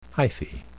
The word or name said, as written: hyphae